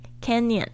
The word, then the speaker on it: Canyon: 我们这边来看一下这个 Grand Grand Canyon 啊，这个 Canyon 比较就是要注意它它的发音，它这个 n 跟那个 y 可以结合在一起念 Canyon。